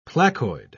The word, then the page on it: placoid: British English